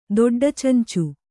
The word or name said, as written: ದೊಡ್ಡಚುಂಚು